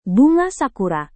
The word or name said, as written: bunga sakura